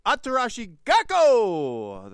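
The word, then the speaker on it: Atarashii Gakko!: I should say that correctly, actually. Atarashi Gakko! That's right, there's an exclamation point at the end of that song, name of the band.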